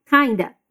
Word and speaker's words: kind of: Here we have a reduction in the pronunciation of kind of. It sounds like kinda. Repeat. I'm kinda swamped.